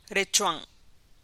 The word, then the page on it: riga’a: Teotitlán del Valle Zapotec